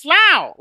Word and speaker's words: slough: "It's pronounced slough, like cow," Conscience yelled.